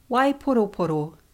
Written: waiporoporo

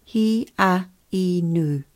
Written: hiainu

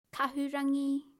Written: kahurangi

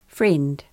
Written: friend

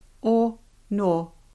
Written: ono

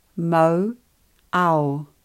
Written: Mauao

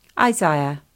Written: Isaiah

